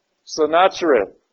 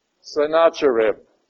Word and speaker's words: Sennacherib: Sennacherib. All right. Sennacherib.